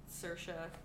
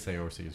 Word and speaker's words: Saoirse: Saoirse, that's how you say her name. – Is that how you say her name? – Saoirse. – I think Saoirse is cooler. – I would not guess that. – I always thought it was like Saoirse or something. But it's Saoirse.